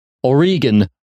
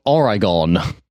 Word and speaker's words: Oregon: Throughout the movie, Oregon is mispronounced. Oregon, not Oregon.